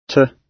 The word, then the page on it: to: British English